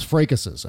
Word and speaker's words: fracas: But that's not how you say it. – What? How do you say it? What is it? – Fracas. – Fracas. – Fracas. Oh, I say. – In French, it's fracas, which is a perfume that is amazing. But it's a fracas.